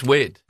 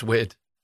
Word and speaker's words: Cynwyd: I think it's wid. – It's, I mean, I know it's spelled that way, but...